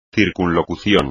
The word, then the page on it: circunlocución: espagnol